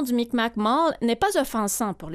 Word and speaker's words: Mi'kmaq: Et selon mes recherches, on prononcerait donc Mi'kmaq. Ben, le Q mm-hmm. final, c'est un son guttural dont on dit qu'il n'existe pas en français, là, mais on va dire Mi'kmaq.